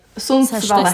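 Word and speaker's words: Sundsvall: Začníme v Sundvalde. – Sundsvale. strašné tieto výslovnosti.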